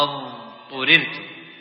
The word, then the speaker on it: اضطررتم: ولا تقول إلا ما اضطررتم، لابد ما تنطق الضاد هنا مستطيلة فصلها عن الطاء، إلا ما اضطررتم.